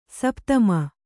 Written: ಸಪುತಮಿ

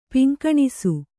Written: ಪಿಂಕಣಿಸು

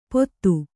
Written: ಪೋತು

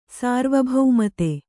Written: ಸಾರ್ವಭೌಮತೆ

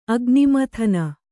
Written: ಅಗ್ನಿಮಥನ

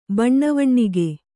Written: ಬಣ್ಣವಣ್ಣಿಗೆ

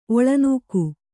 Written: ಒಳನೂಕು

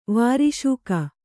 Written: ವಾರ್ಷಿಕ

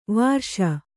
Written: ವರ್ಷ

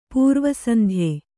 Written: ಪೂರ್ವಸಂಧ್ಯೆ

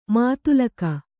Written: ಮೂದಲಿಕೆ

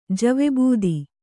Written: ಜವೆಬೂದಿ

